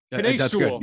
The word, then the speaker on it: Penei Sewell: That is the pronunciation, is it Penei Sewell? Am I saying it right, Thor? – Close enough. Yep. – Penei Sewell. – Good. Yeah. Yeah. – Penei Sewell.